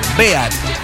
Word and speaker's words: Beat: Con Bealta EAT. Es Beat. Beat. Con la T final. Beat. ¿Ya? Beat. Y se pronuncia Beat.